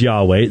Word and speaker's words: Yahweh: They recognize that most scholars identify the more likely pronunciation, well, certainly more likely, because Jehovah can't possibly be the actual pronunciation, as Yahweh.